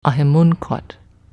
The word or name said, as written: Ahimunquat